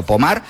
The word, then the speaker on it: Pommard: Se escribe Pommard, como si fuera en castellano, pero Pommard, pues allí en Francia, hablamos de unas imágenes.